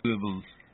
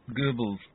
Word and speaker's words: Goebbels: Goebbels, believe. Goebbels. See, I have a U.S. Army phrase book from the Second World War. – Mm-hmm. – And it tells people to pronounce that umlaut O as like that.